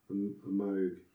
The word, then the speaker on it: Moog: They just have a Moog, is how you're supposed to pronounce it, a Moog synthesizer just squawking through the song.